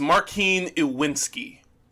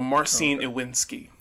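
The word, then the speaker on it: Marcin Iwiński: No, it was he's Polish, so I might fuck it up. But it was Marcin Iwinski. Or Marcin Iwinski. – Oh, Marcin Iwinski? – Marcin, yeah.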